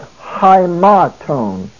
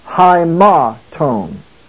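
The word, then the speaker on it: high ma tone (αἱμάτων): It is high, not I, but high ma tone. The second syllable, ma, has the accent mark. High ma tone.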